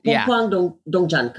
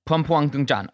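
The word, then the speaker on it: Pumpuang Duangjan: Pumpuang Duongjan, correct. That's right. – Pumpuang Duongjan.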